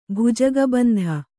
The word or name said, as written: ಭುಜಗಬಂಧ